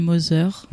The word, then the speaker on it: Moser: Et ça sera suivi du second choix de Manon avec Chloé Moser. Ça peut se prononcer comme ça ou c'est Moser? – Moi je dis Chloé Moser. – Ouais, j'ai pas réfléchi, j'avoue. – C'est un S.